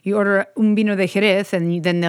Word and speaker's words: Jerez: J-E-R-E-Z. So it's pronounced Jerez. You're ordering, can I get a vino de Jerez?